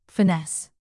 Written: finesse

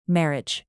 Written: marriage

